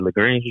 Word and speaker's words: LaGrange: I think it's LaGrange. Is it LaGrange, Georgia? – Yes. – Is that how you pronounce it? – Uh-huh.